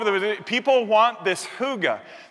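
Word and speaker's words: hygge: It looks like Hygi or Higgy or something, but it's actually pronounced huga.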